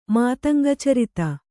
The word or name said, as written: ಮಾತಂಗಚರಿತ